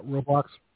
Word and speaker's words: Roblox: And all of her sons like their devices, and their favorite app is Roblox. Did I pronounce that correctly? – Yeah. – Roblox, yeah.